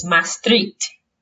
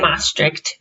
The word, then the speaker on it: Maastricht: Yes, it is Maastricht. Okay. Or Maastricht.